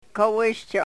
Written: kowishcho

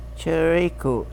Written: cheriku

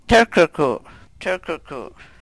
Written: trkrku

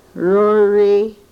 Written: rori

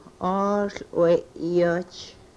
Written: oohl we-yoch

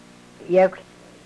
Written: yekwhl

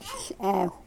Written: hlew

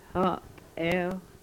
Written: Hopew

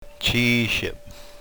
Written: chiishep

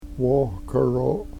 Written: wohkero

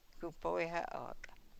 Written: ku poy ho ook

